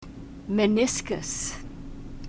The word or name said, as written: meniscus